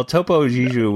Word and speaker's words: Topo Gigio: Popo Gigio. – Not Popo. – Popo Gigio. – Well, Topo Gigio was before our time anyway, so it's okay really that you don't know how to pronounce it.